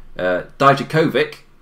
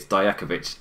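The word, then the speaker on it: Djokovic: So, I think that's an American thing where, for some reason, the Super Croats who moved to America hard pronounce all the syllables, whilst here in Europe, it's a lot softer. So, Dijakovic, it is in America, whilst over in Europe, it's Dijakovic.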